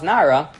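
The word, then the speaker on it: Nara (נער): If Revelvel took out the Sefer Torah on Shabbos day and it had some wax that fell on the word Nun Ayin Resh, which is pronounced Nara, Revelvel would say, no problem.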